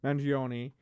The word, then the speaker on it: Mangione: How do you pronounce the last name? – Mangione.